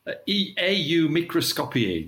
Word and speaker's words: AU Microscopii: AU Microscopii, to give it its Latin pronunciation, or usually known as AU Mic.